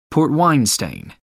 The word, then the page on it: port-wine stain: American English